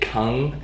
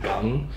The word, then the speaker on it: Gung: He said different regions pronounced it Kung, and others pronounced it Gung. Okay. And so he stuck with the Gung because it was cooler or different or whatever, so.